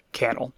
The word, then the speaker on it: Cannell: And the the script for this one is by Stephen Cannell, who we learned is pronounced Cannell, not Cannell, thanks to a video that Epi sent me. So apologies for our previous episodes where uh, I mispronounced that name. It is in fact Cannell.